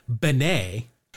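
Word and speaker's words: Bene: his last name is pronounced Benet.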